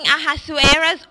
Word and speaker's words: Ahasuerus: Ahasuerus or King Xerxes